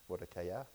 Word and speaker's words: Mordecai: I've been saying Mordecai from Mordecai. That's probably the right pronunciation. Mordecai.